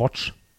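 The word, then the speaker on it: Łódź: Oder wie der Pole sagt, Watch. Ich glaube, das L wird, dieses durchgestrichene L wird wie ein W ausgesprochen.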